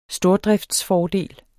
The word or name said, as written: stordriftsfordel